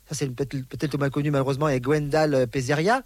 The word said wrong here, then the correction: Pézera: Pézera. – Qu'est-ce que je dis ? Pézeria. – Non, c'est Pézera.